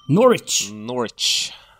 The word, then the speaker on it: Norwich: Norwich.